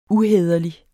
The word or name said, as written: uhæderlig